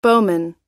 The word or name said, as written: bowman